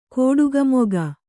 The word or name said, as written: ಕೋಡುಗಮೊಗ